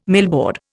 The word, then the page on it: millboard: Indian